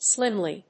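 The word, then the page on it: slimly: slim･ly; slɪ́mli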